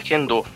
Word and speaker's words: Kendo: Aqui no caso tem, aqui no caso é Kendo a pronúncia.